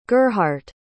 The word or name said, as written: gerhardt